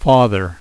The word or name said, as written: father